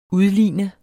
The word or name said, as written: udligne